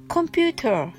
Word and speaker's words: computer: computer は t を l で発音します。ですので computer のようになります。